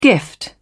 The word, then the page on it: gift: British English